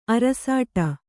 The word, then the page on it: ಅರಸಾಟ: arasāṭa